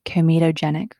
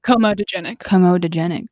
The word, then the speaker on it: comedogenic: And choose a good uncomedogenic oil. Did I say that right? Comedogenic, I think. – Comedogenic oil.